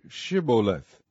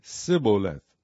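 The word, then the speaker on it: Shibboleth: If he said, Nay, then said they unto him, Say, Shiboleth. And he said, Siboleth. for he could not frame to pronounce it right.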